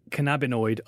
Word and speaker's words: cannabinoid: Well, going back to scromiting, I thought it would be a good idea to speak to someone who has suffered from cannabinoid. I've realised I've been pronouncing it wrong. Cannabinoid.